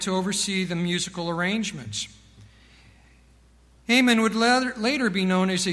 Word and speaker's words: Heman: David also appointed Haman. It's spelled H-E-M-A-N. Looks like He-Man, but that sounds kind of weird. So I say Haman. That may sound weird too. H-E-M-A-N. Haman.